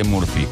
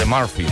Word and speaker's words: Murphy: que se llama la ley de Murphy o de Murphy, mejor dicho, mejor pronunciado.